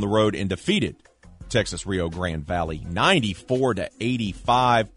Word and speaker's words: Rio Grande: I always say Rio Grande. – Rio Grande. There we go. The E is silent.